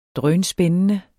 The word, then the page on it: drønspændende: ˈdʁœːnˈsbεnənə